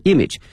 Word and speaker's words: image: 为了提升哈北京的国际形象，我们北京呢发起了一场消除中式英语哈路标的一个比赛，提升这个词非常简单，raise r a i s e 后面加一个 image i m a g e 啊，我们讲过这个词 image，但是同学们注意啊，这个词的发音哈，它的重音放到前面哈，image image raise image。